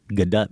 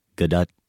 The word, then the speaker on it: Gadot: We are going with Gadot because we heard her say that on Jimmy Kimmel. – Confirmed. – We can show you the link. That's how she pronounces her name.